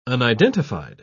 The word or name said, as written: unidentified